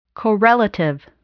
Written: correlative

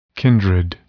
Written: kindred

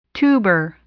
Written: tuber

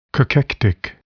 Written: cachectic

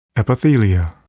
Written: epithelia

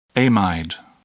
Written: amide

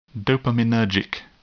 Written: dopaminergic

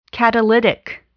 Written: catalytic

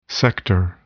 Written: sector

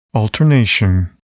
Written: alternation

